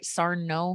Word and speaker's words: Sarno: And also Dr. Sarno. Am I saying that right? Sarno? – S-A-R-N-O.